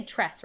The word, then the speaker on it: Tress: You say Tress, right? That's how you pronounce it? – I believe so.